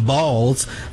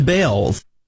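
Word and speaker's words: Baals: After all, the family did change the pronunciation of their last name from Balls to Bales.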